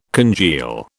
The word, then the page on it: congeal: British English